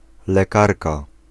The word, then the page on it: docteur: /dɔk.tœʁ/